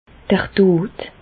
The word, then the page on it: d'r Dot: Bas-Rhin; Reichshoffen